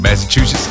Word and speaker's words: Massachusetts: Eastbound US 92 approaching North Massachusetts Avenue. Did I say that right? Massachusetts? – Yes, you did.